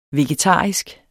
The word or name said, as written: vegetarisk